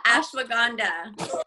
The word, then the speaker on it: Ashwagandha: I take, I think I've always said it wrong. What is it? – Ashwagandha. – I've been saying it wrong the whole time.